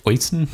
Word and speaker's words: Oisin: I believe his name is pronounced Oisin.